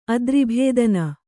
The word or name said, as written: ಅದ್ರಿಭೇದನ